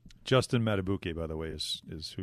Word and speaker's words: Madubuike: Madubuike.